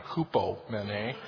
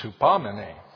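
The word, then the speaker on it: hupamene (ὑπομονή): He did pronounce the Greek word wrong, though, Brother Dan. It's not hupomene, it's hupamene.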